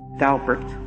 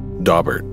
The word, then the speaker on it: Daubert: Daubert. – Daubert. Or as it's often pronounced, Daubert.